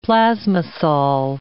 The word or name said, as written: plasmasol